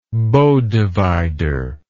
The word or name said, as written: bow divider